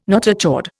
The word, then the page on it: notochord: Indian